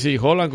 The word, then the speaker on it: Haaland: Haaland, como le dicen, como se pronuncia realmente, pero todo el mundo lo identifica como Haaland.